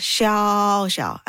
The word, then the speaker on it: Xiao Xiao: I do not even know how to give people the literary step-by-step instruction because letter X does not sound as H. – Yeah. – And then when you say Xiao Xiao and then you tell them the sound, there's no equivalent sound. So how can I teach? So that's the most difficult part. What I can do is to slowly say my name and say, it just Xiao Xiao, and then people can get it.